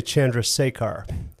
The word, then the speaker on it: Chandrasekhar: Chandrasekhar. – Well, you're actually pretty close. – Thank you. – In America, they say Chandrasekhar.